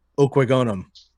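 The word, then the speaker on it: Okwuegbunam: Albert Okwegonum. – Sure. Go for it. – I thought that was flawless pronunciation, by the way.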